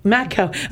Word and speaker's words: Mako: It's Mako like the shark. M-A-K-O.